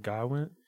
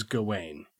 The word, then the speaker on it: Gawain: On print to me, I have always read it in my head as Gawain. – Gawain. – But in the movie, everybody calls him Garwin. – Yeah, they kind of pronounce it with almost like with an R. – Come take your seat next to me, Garwin. – Garwin. – Garwin. But there's no R in it.